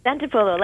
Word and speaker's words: Santopolo: Santopolo, like Marco Polo.